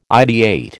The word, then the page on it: ideate: American English